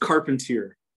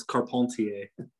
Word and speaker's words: Carpentier: So it's pronounced Carpentier. – Carpentier. Okay. Got it. Yeah. – If you want to get fancy, the French pronunciation is Carpentier.